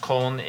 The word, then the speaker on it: Köln: Because it's from Cologne, which is pronounced Köln in Germany.